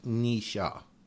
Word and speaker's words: Nietzsche: People often get the name wrong. It's pronounced Nisha. Nisha is a correct pronunciation.